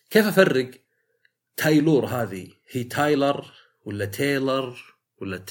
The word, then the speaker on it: Taylor: تايلر وكلها تكتب تايلر.